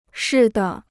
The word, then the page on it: 似的: shì de